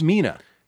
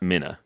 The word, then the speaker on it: Mina: It's a shortened version of Wilhelmina. – Yeah, so it's Mina. – Yeah, not Mina. – Okay, moving on. – I'm with you. – Mina Harker.